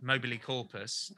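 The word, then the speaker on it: Mobilicorpus: Well, you pronounced it brilliantly. Aww. Mobile corpus, absolutely perfect.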